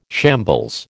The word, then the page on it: shambles: American English